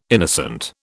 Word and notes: innocent: British English

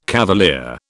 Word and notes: cavalier: British English

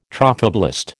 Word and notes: trophoblast: American English

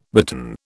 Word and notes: bitten: British English

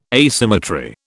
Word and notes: asymmetry: British English

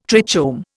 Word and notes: trichome: Indian

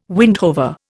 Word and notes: windhover: Indian

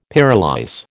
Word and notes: pyrolyse: American English

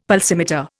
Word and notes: pulsimeter: Indian

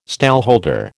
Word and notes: stallholder: American English

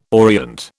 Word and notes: orient: British English

